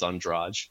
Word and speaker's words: Andrade: I used to call her that too, but the proper pronunciation is Andrade.